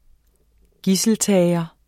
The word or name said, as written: gidseltager